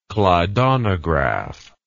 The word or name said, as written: klydonograph